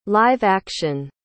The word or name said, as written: live-action